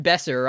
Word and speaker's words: Besser: David Besser rhymes with lesser.